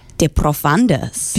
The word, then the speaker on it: De Profundis: De Profundis. – Beautiful. – You'd roll the R.